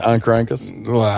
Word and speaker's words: Oncorhynchus: That's how you pronounce it? Oncorhynchus? – Yeah, that's how I pronounce it at least, yeah.